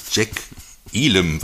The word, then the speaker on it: Jack Elam: Und da haben wir dann halt irgendwie zum Beispiel Burt Reynolds, der mit Dom DeLuise, seinem Partner und diesem durchgeknallten Proktologen Jack Elam, wird der glaube ich ausgesprochen, ja, oder? – Stimmt es.